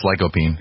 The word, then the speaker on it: lycopene: And this is the stuff that they really say is key, lycopene. Am I pronouncing that right? – I think it's lycopene.